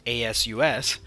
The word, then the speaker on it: ASUS: So, ASUS, pronounced ASUS, that company, it's in a bit of a tight spot.